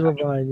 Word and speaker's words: Leavine: Levine. – Andy Levine, yeah. – Yeah. It's spelled Leavine for some reason.